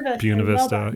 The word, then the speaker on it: Buena Vista: I have to make sure I pronounce that correctly, otherwise people Buena Vista. – Yeah, people get mad at me.